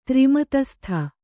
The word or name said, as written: ತ್ರಿಮತಸ್ಥ